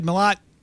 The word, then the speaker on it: Malat: Fat Emo Kid Malat. Do I say your last name right? Is that how you say it? – Yeah, it's a French last name, but we like to pronounce it Malat.